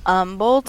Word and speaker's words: Humble: How do you say it? – Humble, Texas. – Yes, not to be confused with humble, though they are spelled the same. I've always been told it's Humble. – Yeah, I mean, everybody I know says Humble.